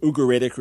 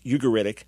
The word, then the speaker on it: Ugaritic: inscriptions were discovered there in the late 1920s, a language hitherto not known called Ugaritic, or as we pronounced it in New York, Ugaritic.